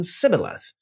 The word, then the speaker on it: Sibboleth: It's the same name, just pronounced different names, Shibboleth and Sibboleth.